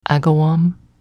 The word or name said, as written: Agawam